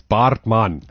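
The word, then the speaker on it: Baartman: Yeah, you so butchered that surname. It's Bartman.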